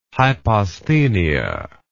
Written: hyposthenia

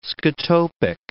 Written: scotopic

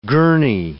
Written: gurney

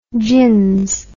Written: jins

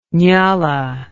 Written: nyala

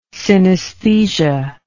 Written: synesthesia

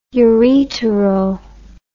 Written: uretero

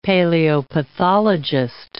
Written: paleopathologist

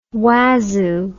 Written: wazoo

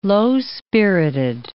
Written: low-spirited

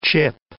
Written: chip

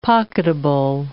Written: pocketable

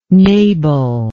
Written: nabal

